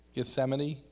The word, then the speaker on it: Gethsemane: It says, "Then Jesus came with them to a place called Gethsemane, I always have a bad place of saying that. Gethsemane, yeah, there it is.